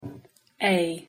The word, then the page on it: a: English